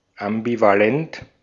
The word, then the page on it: ambivalent: /ʔambivaˈlɛnt/; /ambivaˈlɛnt/